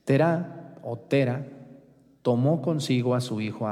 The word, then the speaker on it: Terá: Se llamaba Tera. Acento en la A. Tera. Su mamá de Abraham se llamaba Terá.